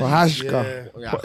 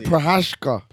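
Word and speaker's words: Procházka: I don't think Prochazka. That's how you say it, Prochazka. – Prochazka. – That's how you say it. All right.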